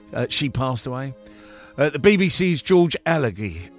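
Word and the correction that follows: Alagiah: uh, sorry, Allergia. Um, I can never say his name properly.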